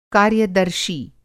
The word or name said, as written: कार्यदर्शी